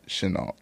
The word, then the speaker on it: Chenault: Chenault? Is that how you say it? – I pronounce it Chenault.